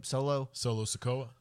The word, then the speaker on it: Solo Sikoa: Solo Sikoa.